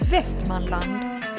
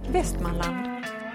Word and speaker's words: Västmanland: Västmanland. Västmanland. Betoningsfel. Men jag säger inte det så ofta, förlåt. – Västmanland. – Vad heter det då? – Västmanland.